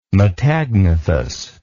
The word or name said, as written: metagnathous